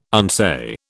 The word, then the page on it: unsay: British English